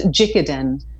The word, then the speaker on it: Jikiden: Jikiden.